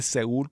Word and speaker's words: Seúl: No sé cómo se dice Seoul en, en Spanish, Seúl, se dice Seúl, Corea del Sur.